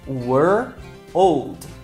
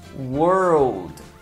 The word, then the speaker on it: world: Comece falando as duas de forma separada, were, old, e vá juntando aos poucos, world, world. Por fim, você vai ter world.